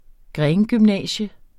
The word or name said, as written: grengymnasium